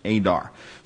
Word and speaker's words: Eder: It looks like Eder, E-D-E-R, but it's pronounced Adar.